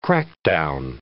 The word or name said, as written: crackdown